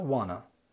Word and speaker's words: wanna: want to لما بنتكلم سريع بيبقى نطقها كانها wanna.